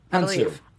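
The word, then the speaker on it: Hounsou: Hansu.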